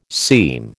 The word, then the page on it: seam: American English